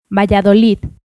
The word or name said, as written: Valladolid